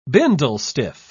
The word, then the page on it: bindlestiff: British English